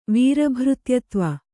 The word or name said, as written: ವೀರಭೃತ್ಯತ್ವ